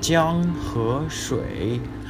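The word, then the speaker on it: Jiang He Shui (江河水): Jiang He Shui.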